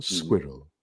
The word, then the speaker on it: squirrel: Bueno, la pronunciación es squirrel, ¿no? Squirrel. Pero en inglés, hablando rápido, a veces suena como squirrel. – Squirrel, yeah. – Casi solo una sílaba.